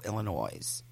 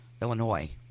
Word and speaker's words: Illinois: She grew up in the neighboring communities of Highland Park and Glencoe, Illinois. Illinois. Um, if you're not from, you know, the S is silent.